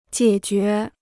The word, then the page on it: 解决: jiě jué